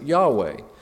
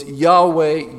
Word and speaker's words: Yahweh: which we add vowels to and we pronounce it Yahweh. So it's Yahweh, Yahweh.